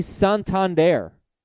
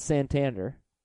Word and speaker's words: Santander: It's Anthony Santander, not Santander.